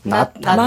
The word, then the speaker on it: 낳다: 아이를 낳다. – 맞아. 맞아. – 맞아. 네. 뒤에 히읗 받침이 있기 때문에 뒤에 다가 이제 그 거센 소리로 발음이 되겠죠.